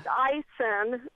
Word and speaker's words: ISON: I-S-O-N? Wow, that's interesting. – Pronounced Eisen with, you know, more of an I-N to it. But spelled the same, yeah. – Oh, okay. Well, Ison, I always say it Ison, even though nobody really knows how it's pronounced, because it's short for International Scientific Optical Network.